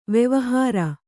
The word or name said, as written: ವೆವಹಾರ